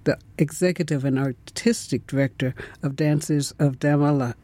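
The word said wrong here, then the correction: Damelahamid: Wait, now I mispronounced that. Could you pronounce it for me properly? – Yes, it's the Dancers of Damla Hamid.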